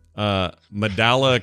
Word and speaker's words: mandala: Quote, when coloring during brain breaks, mandala colored pages will not be used. How do you say that? Mandalia coloring? – Mandala.